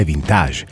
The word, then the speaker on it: vintage: Questo è il nostro mondo ed è vintage. Sì, con la pronuncia francese, come piace a me.